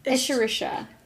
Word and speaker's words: Escherichia: Escherichia? – Escherichia.